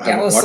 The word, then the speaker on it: Gallus: Uh, Gallus, like l- l- like rhymes with Dallas.